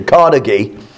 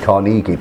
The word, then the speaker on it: Carnegie: Today we're going to look at Andrew Carnegie, and I am informed on from a reliable source that is the correct pronunciation, Carnegie. Most people say Carnegie, but apparently we're not supposed to.